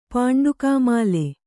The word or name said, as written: ಪಾಂಡುಕಾಮಾಲೆ